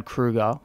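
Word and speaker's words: Kroeger: Corey from Slipknot hates Chad Kruger, and Chad Kruger, which is how you pronounce his name, by the right. – way, rhymes with cougar, which is the type of chicks he likes.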